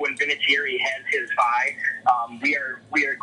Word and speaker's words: Vinatieri: Vinatieri.